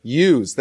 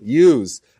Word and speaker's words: ewes: 200 female goats, 20 male goats, 200, I don't know how to pronounce that word even. Ewes, thank you. Ewes.